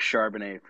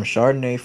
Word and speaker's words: Charbonnet: Zach Charbonnet from UCLA. – Mm, Zach Charbonnet from from, U- from UCLA. Mm, Chardonnay from UCLA. It rhymes, man.